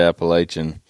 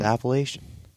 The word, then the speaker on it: Appalachian: Timber Ninja guys give me a hard time for that every time. They're like, really? You're saying it wrong. It's like, it's Appalachian. I'm like, no, it's Appalachian.